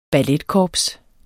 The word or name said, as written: balletkorps